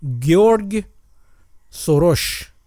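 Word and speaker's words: Soros György: تلفظ مجاریش که خود ادای فردوسی بود در بیاریم در چیز تو گزارشگری فوتبال اینو اینجوری تلفظ میکنن در واقع هستش به صورت احتمالا گیورگ سروش تلفظ خواهد شد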